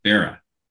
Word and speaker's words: Barat: Borat. There, I was corrected by someone. It's spelled B-A-R-A-T, or was spelled B-A-R-A-T. The T is silent. – Yeah. – Bera.